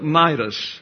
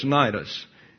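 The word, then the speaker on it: Cnidus: So at Nidus, by the way, that K is silent in case you're wondering what happened to it. It just don't use it. Some letters and words are not used. It's usually not the first letter, but you see right here. In the Greek, it's a K. There is no C in Greek. So you need to get a K sound. It's got to be a K. Okay, so it's Nidus is the way you pronounce that.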